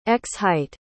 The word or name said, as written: x-height